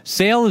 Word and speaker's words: Sale: Sale, as you may be aware, rhymes with fail.